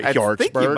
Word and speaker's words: Hjortsberg: I don't know if I'm saying that right. Hjortsberg. – Might be. Yes, that's how you have to say it.